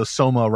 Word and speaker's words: soma: You know, you're in this condition, you're kind of in this drugged state, but also soma rhymes with coma.